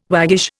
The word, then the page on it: waggish: Indian